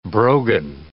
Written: brogan

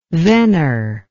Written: vener

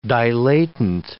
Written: dilatant

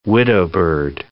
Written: widowbird

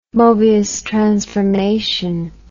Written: mobius transformation

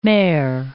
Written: mare

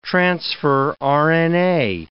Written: transfer RNA

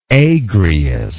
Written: Agrius

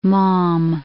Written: Maugham

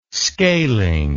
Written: scaling

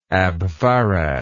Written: abfarad

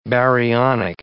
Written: baryonic

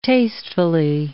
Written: tastefully

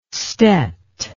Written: stet

